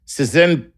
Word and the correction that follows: Szentkuti: S-Z-E-N-T-K-U-T-I. – Sentuki.